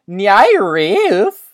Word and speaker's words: NIIRYF: N I I R Y F, which you can remember because it's pronounced Nyarif.